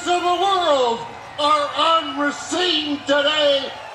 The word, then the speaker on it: Racine: I think you did, because I was saying Racine, and a nice young man in line corrected me. And everyone was like, yeah, idiot, newscaster guy. So Racine, I think, is how it's said.